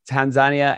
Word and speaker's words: Tanzania: I went to Tanzania. That's the correct way to pronounce it. I've realized Tanzania, and they eat the entire animal.